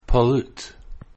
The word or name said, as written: pollute